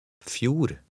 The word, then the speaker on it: fjord: Remember the silent D. – Fjord.